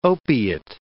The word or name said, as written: opiate